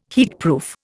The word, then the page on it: heatproof: Indian